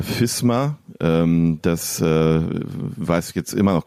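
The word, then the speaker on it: Visma: Und dann haben sie ein Video veröffentlicht, wo Wout van Aert deutlich in die sagt, unsere Mannschaft heißt FISMA, also mit F vorn, FISMA, ja.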